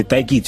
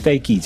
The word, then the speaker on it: Tayc: Et le diminutif, en fait, un peu comme Chris Brown va s'appeler Breezy ou Drake va s'appeler Dreezy, c'était Taïki, tu vois. C'était un peu ça quand j'étais plus jeune que j'avais voulu faire et c'est resté. Donc c'est Taïk, ça s'écrit T-A-Y-C.